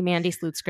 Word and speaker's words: Mandy Slutsker: And I'm the only Mandy Slutsker in the world, according to every possible record that's available.